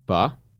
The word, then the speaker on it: pho: Um, pho. I love pho. – What's um, faux? People call it faux. Oh, but it's actually pronounced fa.